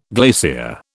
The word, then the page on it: glacier: British English